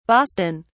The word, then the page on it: boston: British English